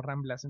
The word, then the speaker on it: Rambles: Entonces, aunque en catalán se con E, entonces Rambles, pero se pronuncia como Ramblas.